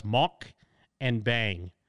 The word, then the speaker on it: mukbang: It's pronounced mok and bang.